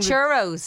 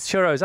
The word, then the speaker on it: churros: Chorios. I never know what Chorios. How do you say it? – Cheerios. – No, the thing. – Oh, churros. – Churros. I can never get my head around that.